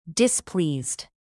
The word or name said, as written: displeased